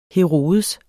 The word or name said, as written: Herodes